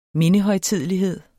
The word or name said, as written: mindehøjtidelighed